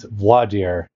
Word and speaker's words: voir dire: So as we kick off this interview, I wonder if you could tell us, how do you pronounce that French term? – I have learned, because I think it all is learned behavior, it's voir dire is the way I learned it.